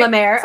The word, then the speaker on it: Leimert: And it was on this day that Elizabeth's body was found in a vacant lot in a largely undeveloped neighborhood known as, I think it's Lemert is how it's pronounced. – Okay. – L-E-I-M-E-R-T. It looks like it's not going to be Lemert. – But no, that sounds right. – Lemert.